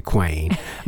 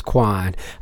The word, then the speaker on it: Kwain: So, Sarah, that's pretty funny because most people, for the first time meeting me, will call me Kwain. Kwain is another one that I'll get from time to time, but it is pronounced Kwan.